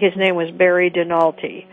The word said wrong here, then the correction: Donalty: I may be pronouncing his name wrong. Yeah. I apologize. – That's okay. Yep. It's Barry Donalty.